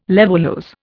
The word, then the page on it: levulose: Indian